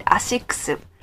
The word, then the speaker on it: ASICS: So, if your Japanese friend is making a confused face like this, try pronounce it ASICS. That ASICS.